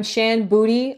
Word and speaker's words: Shan Boody: So I'm Shan Boody online, and Booty is spelt with a D. It is a play on my last name, not my anatomy. So S H A N B O O D Y.